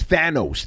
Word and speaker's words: Thanos: How was it again? Thanos? – No, it's Thanos. Thanos. – Thanos. Okay.